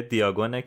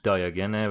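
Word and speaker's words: Diagon: فصل پنجم عنوانش کوچه دیاگونه که حالا تلفظ صحیح‌ترش دیاگنه، ولی خب حالا ما هم دیاگون میگیم که راحت‌تر باشه، یا من دیاگون میگم که راحت‌تر باشه.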